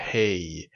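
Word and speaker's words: He: La letra Hey es una letra muda, que se pronuncia como una J muy, pero muy suave.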